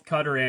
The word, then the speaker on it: Qatar: Technically, both work. Technically, both Qatar and Qatar.